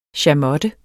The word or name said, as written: chamotte